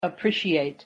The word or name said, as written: appreciate